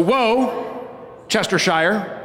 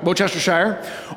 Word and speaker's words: Worcestershire: The accent is on the first syllable of the word. So, Worcestershire, right? Worcestershire. Or, depending on what part of England you're from, because this is a town there, right? It might be Worcestershire.